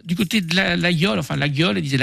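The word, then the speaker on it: Laguiole: Nous sommes allés aussi passer une semaine à L'Aïol, pas la gueule. Hein. On prononce L'Aïol pour les autres.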